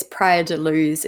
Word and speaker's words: Praia da Luz: So he was living, I think it's Praia da Luz is how you say it in Portugal.